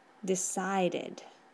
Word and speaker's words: decided: Entonces, la pronunciación de la R de decide sería decided. Decided.